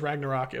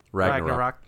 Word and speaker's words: Ragnarok: No, I think it's pronounced in the Ragnarok. You have to put the accent in a different spot. – Wrong emphasis on the wrong syllable.